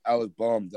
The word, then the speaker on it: Bohm: Alec Bohm. That's how you say his name.